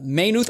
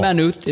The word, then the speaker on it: Maynooth: Yeah, with, and hopefully people look at Maynooth Station, Maynooth or Maynooth. You can pronounce it either ways, but Rick said it's pronounced both ways, but Maynooth Station Lodge.